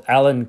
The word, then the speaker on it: Alwyn: That's from one of the top gurus in the fitness world, Alan Cosgrove. Oh, he sounds like if James Bond was a fitness guy. So Alan, so it's spelled Alwyn Cosgrove.